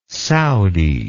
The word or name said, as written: Saudi